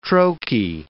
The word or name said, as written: troche